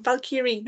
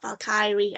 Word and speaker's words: Valkyrie: And I know from rereading Strange Maid about Signy, the young want to be Valkyrie who gets, I keep saying that word wrong, Valkyrie, not Valkyrie. I've always pronounced the I more than the E, and I know that's wrong, but it's just a habit I need to learn, dislearn.